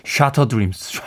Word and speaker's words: Shattered Dreams: 저희는 평생 Shattered d r e a m s 라고 발음을 했는데 어, 노래를 들으니까 영국식 발음으로 발음하네요. 샤터 드림스, 샤터 드림스라고 발음을 해서 음악 듣는 내내 네, 웃었습니다.